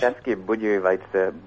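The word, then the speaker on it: České Budějovice: Yeah, you butchered the name. It's Czeski Budějovice, Budějovice Budvar.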